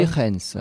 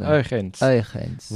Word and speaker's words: Huygens: Vous pouvez, pour le plaisir, nous redire la prononciation à la flamande et à la néerlandophone euh, Huygens. Huygens.